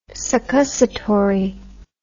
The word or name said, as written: succussatory